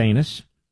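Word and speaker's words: Anas: Is that anus? A-N-A-S. I think it's pronounced anus.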